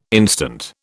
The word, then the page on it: instant: British English